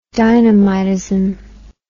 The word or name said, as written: dynamitism